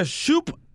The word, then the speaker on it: Shoup: Es en el 58, 58, y se, se pronuncia Shoop Avenue, S H-O-U-P, Avenida en Woodland Hills. – Chup, otra cosa.